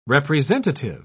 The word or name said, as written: representative